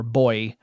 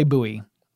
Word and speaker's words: buoy: But it's a portmanteau of sonar and buoy, or boy. I say buoy. That's just the way I've always said that word.